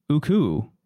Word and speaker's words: Ooccoo: Aku? – Yeah. I think pick your poison. – O-O-C-C-O-O, right? – Yeah. Yeah. Uku.